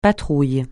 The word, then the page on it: patrouille: francais